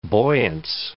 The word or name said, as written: buoyance